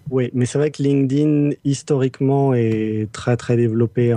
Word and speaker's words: LinkedIn: Euh, la vraie prononciation officielle qu'on est censé faire, c'est LinkedIn. Voilà, ça c'est pour tous les puristes qui auront écouté tout le début du dossier et qui vont nous taper dessus, moi le premier, pour avoir prononcé LinkedIn, prononcez bien LinkedIn comme s'il y avait un T.